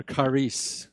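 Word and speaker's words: charis (χάρις): Grace is the Greek word charis, or charis, if you want to pronounce it correctly.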